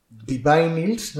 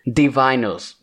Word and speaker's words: Divinyls: Divinyls, ¿no? O Luigi. – Sí, sí. – ¿Cómo se pronuncia? Que luego me dice... – Divinyls. – Divinyls.